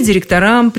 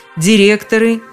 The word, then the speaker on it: директора: Современные нормативные словари никаких проблем с ударением в этом слове не замечают. Только так и никак иначе. А между тем вы можете заметить, как в повседневной речи ваши собеседники все чаще сбиваются на директоров – Директоры, директоров – старая дореволюционная норма.